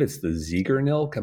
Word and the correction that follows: Zeigarnik: Am I pronouncing that right? The Ziegernilk effect. – The, the Zeigarnik effect.